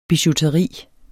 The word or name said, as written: bijouteri